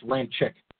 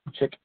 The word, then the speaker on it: Ramczyk: I think that I did read something where they said pronounce that, not Ramczyk, it's Ramczyk. – Chick.